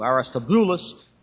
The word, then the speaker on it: Aristobulus: The accent is over on the boo, Aristobulus.